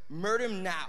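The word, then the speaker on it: merdimnao (μεριμνάω): And the term, when you look at anxiety in the Greek, it's pronounced this way, merdimnao.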